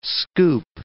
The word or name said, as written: scoop